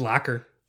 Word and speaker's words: Lockar: L-O-C-K-A-R. The Locker. – Okay. Lockar. Gotcha. – But like, you got to pronounce it like an E. It's the Locker.